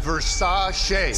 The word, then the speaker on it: Versace: Pronounced Versace.